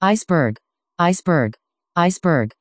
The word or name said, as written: iceberg